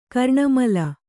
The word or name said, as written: ಕರ್ಣಮೂಲ